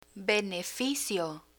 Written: beneficio

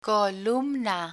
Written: columna

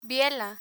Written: biela

